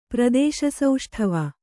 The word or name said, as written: ಪ್ರದೇಶಸೌಷ್ಠವ